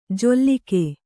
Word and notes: ಜೊಲ್ಲಿಕೆ: kannada

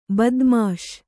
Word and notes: ಬದ್ಮಾಶ್: kannada

